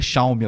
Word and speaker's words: Xiaomi: Mas é Xiaomi a pronúncia correta.